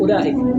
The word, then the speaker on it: أولئك: تكتب شيئا ولا تلفظ به، مثل كلمة أولئك. أولئك أنت ما تقول أولئك، تقول أولئك، لاحظوا همزة ثم لا بينهما واو، هذه الواو لا تنطق.